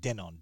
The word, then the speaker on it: Denon: I pronounce it Denon, D E N O N, a pretty popular brand for home entertainment and audio.